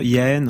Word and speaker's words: Jahen: Et d'ailleurs, Yaen ou qui s'écrit Jaen, euh, J-A-H-E-N, euh, c'est une construction un petit peu voilà.